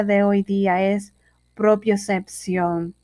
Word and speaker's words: propiocepción: Esto es una palabra aguda, se acentúa en la última sílaba debido a que termina en N. Bueno, espero que le haya gustado esa palabra propiocepción y que puedas reconocerla o, o saberla usar en su vocabulario habitual.